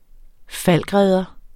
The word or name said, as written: Falckredder